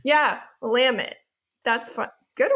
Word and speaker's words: Willamette: And um, for those of you who are not familiar with the Willamette Valley, it looks like it's spelled Willamette, but you say it like you say damn it.